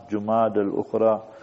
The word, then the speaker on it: جمادى الاخرى: کبھی کبھی ہم دیکھتے ہیں تقویم میں کیلنڈر میں غلط جو ہے طور پر لکھتے بھی ہے تلفظ بھی, تو صحیح ہے جمادى الاولى جمادى الاخرى, تو یہ صحیح تلفظ بھی ضروری ہے.